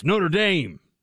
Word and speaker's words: Notre Dame: It's not the University of Notre Dame. It is Notre Dame.